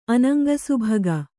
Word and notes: ಅನಂಗಸುಭಗ: anaŋgasubhaga

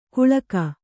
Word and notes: ಗುಳಕ್: guḷak

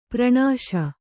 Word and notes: ಪರ್ಣಾಸ: parṇāsa

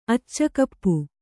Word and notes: ಅಚ್ಚಕಪ್ಪು: kannada

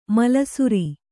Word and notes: ಮೇಲುಸಿರ್: mēlusir

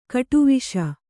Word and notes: ಗೆಡೆವಸು: Kannada

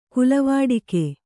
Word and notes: ಕುಲವಾಡಿಕೆ: kulavāḍike

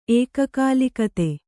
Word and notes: ಏಕಕಾಲಿಕತೆ: kannada

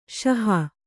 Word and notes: ಷಹ: ṣaha

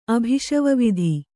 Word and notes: ಅಭಿಷವವಿಧಿ: Kannada

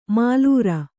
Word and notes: ಮಾಲುರ: mālura

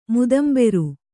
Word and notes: ಮುದಂಬೆರು: mudamberu

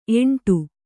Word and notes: ಎಂಟು: eṇṭu; kannada

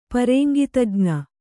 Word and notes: ಪರೇಂಗಿತಜ್ಞೆ: Kannada

